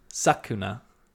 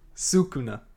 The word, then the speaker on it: Sukuna: We go to Itadori, who's talking to Sakuna, and says... – Terrible. – Say it for me one more time. – we had a pronunciation. Sukuna.